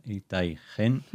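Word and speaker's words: Itay Chen: Está escrito como Chen, se pronuncia Itai Gen.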